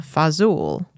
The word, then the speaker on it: fazool: And they brought with them their pronunciation for words. So fasule eventually became fazool.